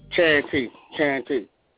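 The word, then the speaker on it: Chant T: This is Chad T, man. Artist checking in. – What's going on, homie? Chad P? – Chad T. – Oh, spell that. – C-H-A-N-T.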